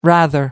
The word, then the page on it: rather: American English